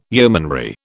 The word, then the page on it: yeomanry: British English